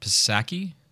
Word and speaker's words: Psaki: Also, Jen Psaki. I think that's how you say her name. I should know it. – Psaki. – Jen Psaki. Okay, I should know it. – Psaki, I think, is right.